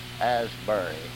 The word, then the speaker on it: Asbury: I put a Ashbury, and it is really Asbury. The Asbury would be correct. I want to get that so whoever listens to these will know when they get to this tape that that was mispronounced on the other tape.